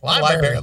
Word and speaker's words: LBRY: And it's spelled just like Jennifer would spell it, LBRY. – Library.